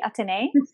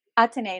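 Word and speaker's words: Athenee: So it's Simply Atene. That's A-T-H-E-N-E-E. I'm going to have to find an easier name for myself because it's so hard to spell. – Yes, the H is silent, right? – Yes.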